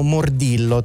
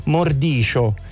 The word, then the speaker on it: Mordillo: C'è un'altra nostra ascoltatrice, Claudia, che scrive che lei aveva i poster di noi in Italia lo chiamiamo Mordillo, tant'è che ormai è stato accettato anche come Mordillo, in realtà si pronuncia Mordicio in argentino, e lei dice: conoscete fumetti meravigliosi e divertenti?